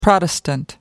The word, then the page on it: protestant: American English